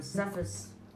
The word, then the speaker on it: Cephas: That he appeared in Cephas, am I pronouncing that right? – Mm-hmm.